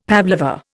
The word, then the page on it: pavlova: Indian